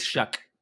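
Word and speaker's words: Shough: It's Shuck. – Yeah. It rhymes with my favorite word.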